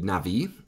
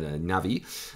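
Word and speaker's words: navi (נביא): The ancient Hebrew words for prophet include navi, that first word there. That B is pronounced a little bit like a V, actually. So navi, which that's the general word for prophet.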